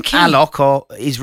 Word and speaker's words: Alok: Oh, so the name Alok is referring to the Brazilian DJ. It's pronounced Aloki. – Well, I'd have never known that. – We've all learned something today, haven't we? – Oh, yeah. – The A is soft, the O is open, and the final I is what? Pronounced.